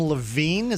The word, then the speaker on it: Levine: Now, she's being coached by Adam Levine. Is that how you pronounce his name? – That's right.